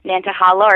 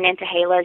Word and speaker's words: Nantahala: Nantahala, or Nantahala, as you'll hear pronounced, means noonday sun.